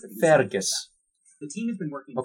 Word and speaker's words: Fergus: Fear Gus. – Fergus Urquhart. – It's you, Fear Gus. Fear Gus. – But it's pronounced Fergus.